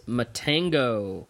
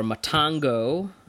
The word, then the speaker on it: Matango: And we are talking about Ishiro Honda's Matango, or Matango. I've heard it pronounced both ways.